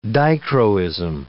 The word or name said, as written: dichroism